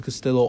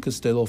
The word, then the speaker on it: Castillo: My TikTok is Colby Castillo, all lowercase. And my LinkedIn is Colby Castillo. First name is spelled K-O-L-B-Y. Last name is C-A-S-T-I-L-L-O.